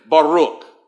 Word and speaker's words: Baruch: Kind of roll your R with me and say Baruch.